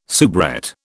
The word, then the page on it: soubrette: British English